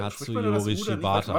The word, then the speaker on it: Katsuyori Shibata: Katsuyori Shibata. Manchmal spricht man das U und manchmal nicht.